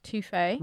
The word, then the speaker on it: TOFE: They're called Toffee, but they're spelled T-O-F-E, but it's pronounced Toffee.